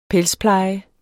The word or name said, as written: pelspleje